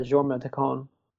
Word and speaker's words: Jorma Taccone: I'm not sure if I pronounce his name right, but Jorma Taccone, however. – I've always heard it Jorma Taccone.